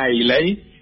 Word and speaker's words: Islay: Bueno, hay todo un grupo de whiskies de una zona del Reino Unido, de Escocia, que se llama Islay, claro. O se pronuncia Islay, que suelen ser whiskies todos con mucho ahumado.